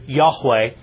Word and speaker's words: Yahweh: Jehovah is actually a mispronunciation of the Hebrew Yahweh.